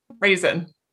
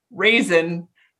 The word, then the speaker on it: Rezin: Because your name is spelled R-E-Z-I-N, and that's how I pronounced it the first time. – Yeah, when I said it, I spelled it out like the dried grape.